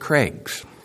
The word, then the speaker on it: crags: The context cries, the crowds cry, the critics cry, and even the crags might cry on Palm Sunday, point four. Who cries on Palm Sunday? Maybe even the crags. Sometimes pronounced Craigs, which, as you may know, because I mention it from time to time, someone named Craig, Craig means of the crag.